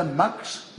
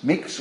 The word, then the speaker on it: Mx: So it's either Mrs. or Mr. You pronounce it, according to the gurus, either mux, mix or mux, which is the only unstressed schwa in English.